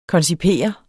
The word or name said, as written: koncipere